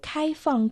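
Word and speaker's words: 开放: «Кайфан» — открытости. «Кай» — первый дон. «Фан» Четвертый дон. Слово «кайфан» в переводе «открытости».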